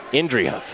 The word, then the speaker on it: Inderhus: Inderhus.